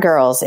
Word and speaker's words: Gurlz: Smart Girls. It's, and the girls is spelled GURLZ.